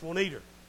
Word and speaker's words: Juanita: In Alabama, the name Juanita is not pronounced that way. It's Juanita. My Aunt Juanita. Like it ends in an R, but it doesn't.